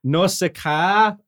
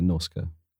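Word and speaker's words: Nausicaä: Here are, I'm going to fucking butcher this name. – Go on. – Let me do it. – Do it. – Nausicaa of the Valley of the Wind. – That was pretty close, actually. Yeah. – Really? – Yeah, Nausicaa.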